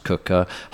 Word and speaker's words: Quooker: This podcast is sponsored by Cooker, spelt Quooker, pronounced Cooker.